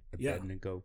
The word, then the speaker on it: Abednego: Abednego.